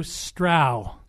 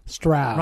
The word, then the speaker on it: Strau: Yeah, I learned it. Andrew Strau. – Strau. – Rhymes with wow.